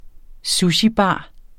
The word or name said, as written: sushibar